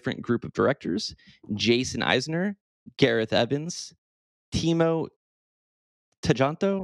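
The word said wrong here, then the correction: Tjahjanto: I'm sorry if I mispronounced that. – I think it's Jahancho. – Jahancho.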